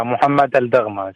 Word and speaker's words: Mohammed Al-Dagma: Yeah, Mohammed Al-Dagma. Same exactly like what you did you say.